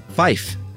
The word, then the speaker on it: fife: To differentiate it from, you know, F-O-R. And five is fife, to keep the word fire distinct in some dialects.